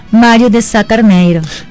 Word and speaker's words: Mário de Sá Carneiro: En 1890 el poeta, novelista y dramaturgo portugués Mário de Sá Carneiro. – Oye, me gustó esa pronunciación tuya, lusitana, me gustó.